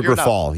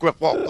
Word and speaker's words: Grifol: Is that how you pronounce his name? I've been trying to Pedro Grifol, yeah. – Yeah, I had a Grifoil. I've been doing it all. I've had 19 different pronunciations. Grifol, good.